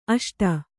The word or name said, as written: ಅಷ್ಟು